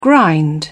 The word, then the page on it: grind: British English